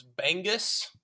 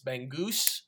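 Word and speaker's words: Bangus: Being the first time I pronounced that was Bengus. And I was corrected pretty quick. It's Bengus.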